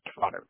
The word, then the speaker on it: Trotter: It's Trotter, T-R-O-T-T-E-R.